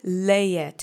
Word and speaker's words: leje: Let's say leje together three times. Accent is on the first syllable. Leje leje, leje.